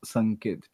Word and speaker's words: Sanket: And there's not an excuse for an Indian to not able to be pronounced Sanket.